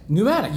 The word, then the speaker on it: pneumatic: It has a P in it. Pneumatic? – Pneumatic. – You don't pronounce the P? – Yep. P-N-E-U-M-A-T-I-C? – Yeah, that's nice.